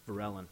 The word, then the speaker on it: Verellen: V-E-R-E-L-L-E-N. – E-L-L-E, Varellen. Varellen? – Varellen.